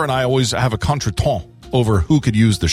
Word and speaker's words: contretemps: It is contretemps. – Oh. – It's got that real silent P and S on the end of it. – Okay. – Contretemps.